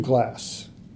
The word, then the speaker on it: Glass: Steve Glass, G-L-A-S-S.